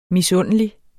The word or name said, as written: misundelig